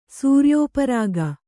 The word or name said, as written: ಸೂರ್ಯೋಪರಾಗ